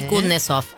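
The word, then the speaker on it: Kusnetzoff: Vuelve, a ver si me sale, Kutnesov. – PH, correcto, a sí, Telefe. Andy Kutnesov, así se puede pronunciar. – Correcto.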